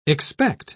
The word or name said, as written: expect